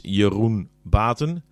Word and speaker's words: Jeroen Baten: So, if you want to visualize that in your Spanish or English, if you would do a Y-E-R-U-N and pronounce that in English, then you have something that sounds pretty familiar to Jeroen.